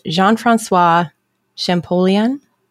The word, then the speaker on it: Jean-François Champollion: And between the years of 1822 and 1824, a French scholar, whose name I'm going to totally butcher, named Jean-François Champollion. – Actually, that sounds totally legit. I don't think you butchered it at all.